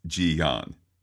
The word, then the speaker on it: Ji-yeon: Well, I only speak a little Korean, enough to know that this is pronounced Ji-yeon.